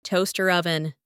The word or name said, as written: toaster oven